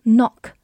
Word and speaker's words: knock: 好，我们对比一下它和另一个词的发音，k n o c k 读作 knock，它是一个鼻音 knock。